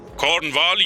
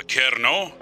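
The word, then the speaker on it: Kernow: کورنوال یا کرنو که در واقع تلفظ محلیش محسوب میشه، یه منطقه باستانیه که سرشار از میراث افسانه‌ایه.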